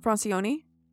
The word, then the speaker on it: Francioni: Francioni? – Yes. The Francioni family lived in Gentilly, Louisiana. – Gentil. – It's G-E-N-T-I-L-L-Y.